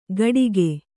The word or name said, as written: ಘಟಿಗೆ